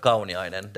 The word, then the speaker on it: Kauniainen: Aj, det, det var också hur man ska uttala äh, Kauniainen där på, på amerikanska. Första är Kau, C-O-W.